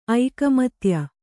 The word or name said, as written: ಐಕಮತ್ಯ